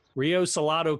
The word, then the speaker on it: Rio Salado: and an adjunct professor for Rio Salando. Did I say that right? – Rio Salado College, also a clinical associate professor at Arizona State University.